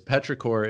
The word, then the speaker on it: petrichor: What it actually is, petrichor is spelled P-E-T-R-I-C-H-O-R. Petri, and then core, like chorus, except without the us. Like petri dish, chorus, no us.